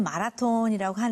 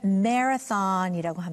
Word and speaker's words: marathon: 한국 사람들은 마라톤이라고 하는데 미국 사람들은 발음을 marathon이라고 합니다. marathon 이런 marathon에서 마라톤에서 able-bodied athletes can run a 42-kilometer marathon in about 2 hours.